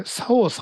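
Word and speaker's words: 市川沙央: まず作家の方の名前が市川、これ、佐王さんって読むんですか。 – そうですね。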